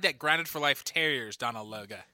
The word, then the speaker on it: Donal Logue: Is Donald Logue, am I saying that right? – I always pronounce it as Donal Logue.